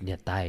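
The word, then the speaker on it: niam tais: So I corrected it. It was like, it's Nia Thai, you know? She got so mad, dude. She hit me. You know? You saying it wrong though, bro. Like you ain't not saying it right.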